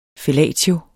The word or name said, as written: fellatio